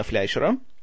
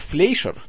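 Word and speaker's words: Fleischer: А, фильм был режиссерским дебютом бывшего клипмейкера и режиссера рекламных роликов Рубина Фляйшера. У нас почему-то называют его Флейшер, но как бы оригинальное немецкое произношение его фамилии все-таки Фляйшер.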